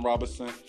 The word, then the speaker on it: Robinson: It's spelled N-E-S-A-H-N Robinson, R-O-B-I-N-S-O-N, Nesahn Robinson. And I'm on Instagram, Nesahn Robinson.